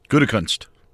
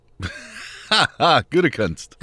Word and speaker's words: Gutekunst: Gutekunst. – Gesundheit. How do you say his last name? – Gutekunst.